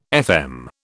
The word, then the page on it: FM: British English